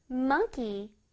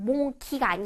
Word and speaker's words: monkey: 원숭이는 영어로 monkey. monkey. 자, 요번에는 M-O-N-E 빨간색으로 되어 있네요. 자, 강하게 발음해 줄게요. 자, 원숭이, mon, M-O-N을 뚜렷하게 발음해 줘야 돼. monkey. monkey. 그냥 몽키가 아니야. 몽키, no, no, no.